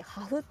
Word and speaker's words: HAFH: っとねアルファベットで HAFH って書いてハフって読むんだけど、